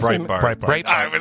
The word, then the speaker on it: Breitbart: Breitbart.